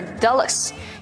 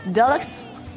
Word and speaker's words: Dulux: Now here, Dulux has got two pronunciations. You can call it Dulux or Dulux. Both are okay.